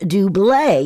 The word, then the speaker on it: Double: And yes, it is pronounced Dublé, even though it's spelled D-O-U-B-L-E, which in the U.S. is double.